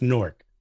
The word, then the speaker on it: Newark: It's Newark, but Newark. We don't pronounce it that way. It's Cork without the C, put an N. It's Newark.